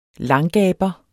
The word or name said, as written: langgaber